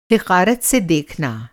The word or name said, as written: हिक़ारत